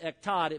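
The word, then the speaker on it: ektad (אחד): Now, the Hebrew word for one here is the word, well, in Hebrew it looks like the word ache, but it's actually the word ektad. You pronounce it ektad.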